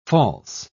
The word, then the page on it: false: British English